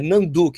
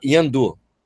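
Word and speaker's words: Nhandu: A, a organização da, da prova é a Fundação Yandu, Nandu, que fala, é Nandu que, que se escreve, mas se fala Yandu, Tá?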